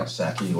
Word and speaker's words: Psaki: Psaki, whatever.